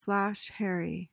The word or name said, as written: flash harry